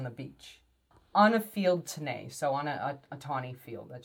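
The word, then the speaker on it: tenné: So that word that looks like tenny is pronounced tenay.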